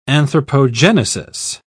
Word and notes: anthropogenesis: British English

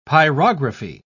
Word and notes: pyrography: British English

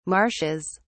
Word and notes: marshes: British English

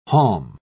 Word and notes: haulm: British English

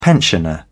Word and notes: pensioner: British English

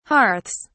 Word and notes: hearths: British English

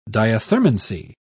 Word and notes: diathermancy: British English

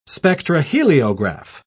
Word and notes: spectroheliograph: British English

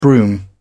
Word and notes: brougham: British English

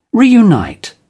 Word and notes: reunite: British English

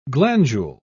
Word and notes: glandule: British English

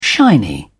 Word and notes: shiny: British English

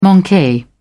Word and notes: manque: British English

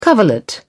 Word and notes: coverlet: British English

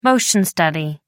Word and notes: motion study: American English

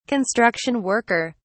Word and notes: construction worker: British English

